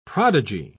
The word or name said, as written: prodigal